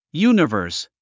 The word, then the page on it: universe: júːnəvə`ː r s; ユニヴァース